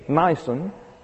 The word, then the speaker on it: Nisan: So when the first month of the new year begins, Nisan, that's N-I-S-A-N, long I, you've got a new moon, and you count off 14 days, and that's Passover.